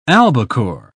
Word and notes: albacore: British English

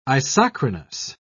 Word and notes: isochronous: British English

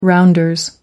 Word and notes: rounders: American English

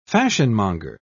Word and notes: fashionmonger: British English

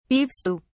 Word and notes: beef stew: British English

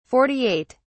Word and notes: 48: British English

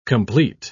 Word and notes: compleat: British English

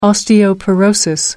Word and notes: osteoporosis: American English

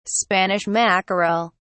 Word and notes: spanish mackerel: British English

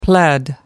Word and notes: pled: American English

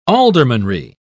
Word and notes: aldermanry: British English

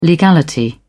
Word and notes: legality: British English

